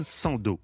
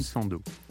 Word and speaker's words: Sandow: Eugène Sandeau. – Eugène Sandeau. – Ça s'écrit comment ? – S-A-N-D-O-W. – Ah, alors peut-être qu'on prononce Sandoff, peut-être. Sandow. Kaliningrad, oui, on devait dire.